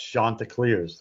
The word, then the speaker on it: Chanticleers: So is it Chanticleers? Do I have that right? – Yes, Chanticleers. That is correct.